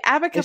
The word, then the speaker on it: abaca: I think it's abaca. – Pronounce like an abacus. – What's an abacus? – The old timey calculator thing that we used to have. Abaca. Abaca. Is that what you said? – Yeah.